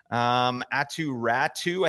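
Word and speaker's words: Aatu Räty: Atu Ratu, I think is how you pronounce his name.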